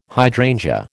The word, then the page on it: hydrangea: American English